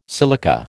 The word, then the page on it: silica: American English